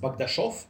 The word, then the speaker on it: Bogdashov: Oh, well, But in all seriousness, it's actually my last name is pronounced Bogdashov. – Oh, Bogdashov. – Yeah, and the reason for that, because it's the CH sound.